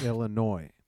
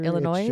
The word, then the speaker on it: Illinois: I believe, correct me if I'm wrong, I believe the S is silent and it's Illinois. And oh. I've heard you say Illinois a lot. And I'm pretty sure it's not Illinois.